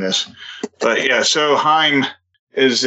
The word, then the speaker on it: Haim: No, it's Haim. Rhymes with time.